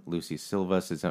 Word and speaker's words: Lucy Silvas: You've been playing with Eastland, Lucy Silvas. Am I saying that right? – Mm-hmm. – Lucy Silvas, Thompson Square, Brandy Clark, Lindsay L., and of course, Dan Tominski.